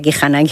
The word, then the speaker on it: Kihana: Yo misma también decía kihana muchas veces, en lugar de decir Kihana, que es un poco más complicado. Bueno, el caso es que la H muda y es Kihana, ya está, ¿no?